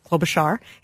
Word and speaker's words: Klobuchar: Amy Klobuchar, and he said he got a deep dive immersion lesson on how to pronounce her name, Klobuchar. He said some people say Klobuchar, but yeah, most of us say Klobuchar.